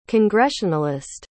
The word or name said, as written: congressionalist